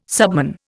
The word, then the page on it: subman: Indian